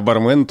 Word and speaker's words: бармен: Но в данном случае бармен норма, норма строга и требует от нас ударения на первом слоге.